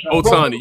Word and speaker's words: Ohtani: Is it Ohtani, y'all? – Joey Ohtani. I'm not sure. – I'm going to try to say his name. Just say the Japanese guy. – Ohtani, yeah.